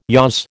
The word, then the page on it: yaws: American English